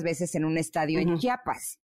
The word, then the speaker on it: Chiapas: Chiapas, Chiapas, que lo estaba pronunciando mal, tenía que decir Chiapas.